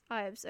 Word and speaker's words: Ives: Is it Grace Ives or Grace Eves? – Ives.